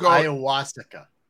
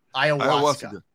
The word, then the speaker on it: ayahuasca: It's pronounced ayahuasca. Ayahuasca.